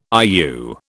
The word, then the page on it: IU: British English